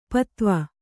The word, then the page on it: ಪದುವ: paduva